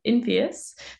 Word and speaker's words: Infyos: And the team over at Infoios is trying to do something about that, although I'm probably pronouncing it wrong. Do you want to take it away? – Awesome. You should be able to see my screen now. Is that up there? – You are golden and good to go. – Great. So yeah, hi everyone. Co-founder and CEO of Infoios. So very close with the pronunciation.